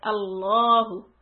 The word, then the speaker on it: Allahu: Sometimes we make the middle lam with the shadda on it. We make that lam heavy. Lam fatha becomes la. Like Allahu. Allahu.